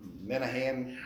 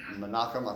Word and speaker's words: Menachem: Remember you're discussing Menachem, or Menahem, Menachem, I think is how the Jews pronounce it.